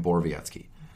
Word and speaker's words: Borowiecki: For hits, I'm going to Mark Borowiecki, which I finally learned how to pronounce. So I had to look it up on YouTube, but I got it down now. So Mark goddamn Borowiecki.